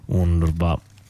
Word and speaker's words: UNRWA: UNRWA, difficile da pronunciare, ma si chiama così, con la doppia V.